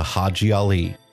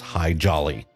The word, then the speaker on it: Haji Ali: He converted to Islam and changed his name to Haji Ali, which members of the U.S. Cavalry pronounced as High Jolly.